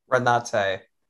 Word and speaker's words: Renate: Renate.